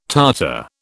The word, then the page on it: Tartar: British English